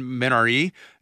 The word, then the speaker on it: Minari: Mank, Minari, or if you want to say it correctly in Korean, Minari.